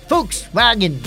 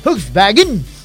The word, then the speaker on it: Volkswagen: Pero ang pronunciation talaga niyan is Volkswagen. Volkswagen. Volkswagen, di ba? Volkswagen, German Totoo na yan, mga kaibigan.